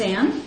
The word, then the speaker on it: Anne: As you know, my name is Anne, and it's spelt with an E.